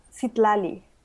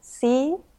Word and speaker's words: Citlalli: My name is pronounced Citlalli. It's like if you divide it in three syllables, it's Citlalli.